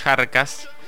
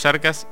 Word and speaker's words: Kjarkas: Bueno, los Yarcas, creo que se pronuncia los Yarcas. Yo tuve el placer de verlos en vivo. – Se escribe Kjarkas. – Kjarkas.